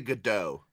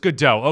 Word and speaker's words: Gadot: And I know that we just mocked The Rock, Gal Gadot, and, by the way, can we just decide on how to pronounce her last name? Can she tell us, actually? – I say Gadot. – It's Gadot. Okay, because I just said Gadot, which is completely fucking wrong.